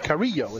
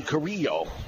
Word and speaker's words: Carrillo: And that's E.P. Carrillo, it's pronounced. – E.P. Carrillo. – Yeah. Ernesto. Double L is a Y.